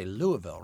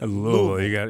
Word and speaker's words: Louisville: Wait, did they say Louisville, right? – Louisville. You got to say it like you're slightly drunk.